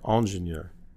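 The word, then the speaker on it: ingénieur: Well, it's spelled E-N-G-I-N, mm-hmm. right? But if you speak French or Spanish, a Latin language, engineer begins with an I, ingenieur. I-N-G-E-N.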